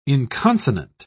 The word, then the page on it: inconsonant: British English